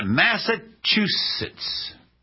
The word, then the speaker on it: Massachusetts: In Massachusetts. I did it. Is that the way? Yeah, Jeff said I said it right.